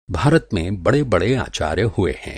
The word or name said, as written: आचार्य